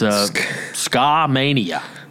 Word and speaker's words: Skamania: Skamania County, Washington? It's Skamania.